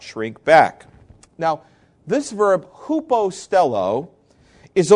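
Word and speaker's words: hupostello (ὑποστέλλω): Paul uses a verb that is pronounced hupostello, hupostello. It begins with the vowel upsilon, which looks like a U when it's written out in English, hupostello.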